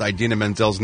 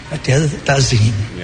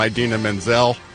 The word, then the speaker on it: Idina Menzel: John Travolta became the laughingstock at Sunday night's Academy Awards when he mispronounced Idina Menzel's name. Of course, he called her. Well, let's play the clip. – The wickedly talented one and only Adele Dazeem.